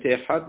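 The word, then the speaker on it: احاد (אחד): دالت، دالت احاد میگه فهمان خوب تلفظ کنیم که رش تلفظ نشه، خواست شدم اشم اخر خدای دیگریه.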